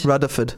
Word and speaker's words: Rutherford: You know, you've got to say it right. – Rudderford. – It's a D, not a TH.